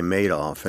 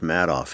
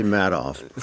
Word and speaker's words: Madoff: You also always say Madoff, and it's Madoff. He made off with the money. It's actually Madoff.